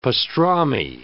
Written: pastrami

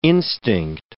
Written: instinct

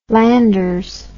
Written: landers

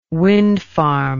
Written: wind farm

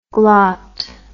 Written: glot

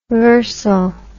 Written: varsal

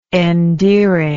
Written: endearing

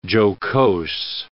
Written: jocose

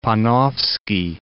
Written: Panofsky

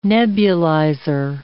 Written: nebulizer

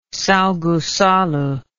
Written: Sao Goncalo

